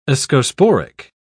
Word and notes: ascosporic: British English

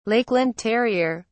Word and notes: Lakeland terrier: British English

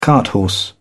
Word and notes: carthorse: British English

